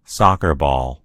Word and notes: ball: American English

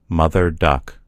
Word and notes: duck mother: American English

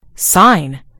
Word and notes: sign: British English

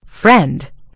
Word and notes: friend: British English

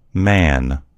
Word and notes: man: American English

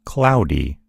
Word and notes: cloudy: American English

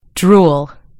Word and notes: drool: British English

